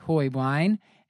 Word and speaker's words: Heublein: And we are happy to have with us tonight Dr. Molly Hoiblein, and I'm pretty sure I'm pronouncing that right, from UCSF. Hi, Dr. Hoiblein. Hoiblein. – Hi. – I almost did it. – You got it right the first time.